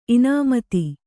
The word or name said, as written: ಇನಾಮತಿ